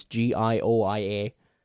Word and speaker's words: Gioia: I don't know how you pronounce his name. It's Gioia.